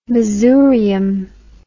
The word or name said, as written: masurium